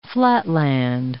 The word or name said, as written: flatland